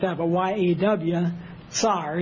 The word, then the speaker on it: Tsar: But it's pronounced like that, like she would have pronounced it, Tsar.